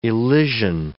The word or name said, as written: elysian